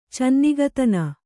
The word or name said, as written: ಚೆನ್ನಿಗತನ